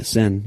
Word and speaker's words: Seine: The Seine, yeah.